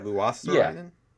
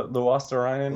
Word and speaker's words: Luostarinen: Luos- I still can't pronounce it right. – Luostarinen. – Yeah. Luostarinen.